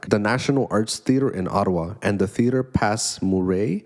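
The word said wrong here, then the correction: Passe Muraille: sorry if I butchered that, Murray Murai, Theater Pass Murai, in Toronto are the latest venues to embrace this concept, which began on Broadway last year, right?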